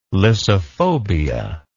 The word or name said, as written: lyssophobia